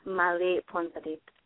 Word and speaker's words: Mali Phonpadith: Molly Phonpadith.